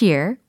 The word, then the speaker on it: year: This year, 올해에 해당하는 year의 발음은 year, year, 요렇게 됩니다. year. 근데 귀에 해당하는 단어 있잖아요. ear. 그게 바로 ear, ear. 요렇게 발음이 되는 거예요.